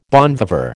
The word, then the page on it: bon viveur: American English